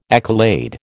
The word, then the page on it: accolade: American English